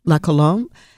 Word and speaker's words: La Colombe: Is it La Colombe? Is that how it's pronounced? – La yes. Colombe. – La Colombe.